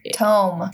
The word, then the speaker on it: tome: So, at Yale University, in the Beinecke Rare Book and Manuscript Library, sits possibly the most mysterious tomb of human history. It's called the Voynich Manuscript. Okay? – Speaking of mispronunciation, okay. Tomb? – Tome.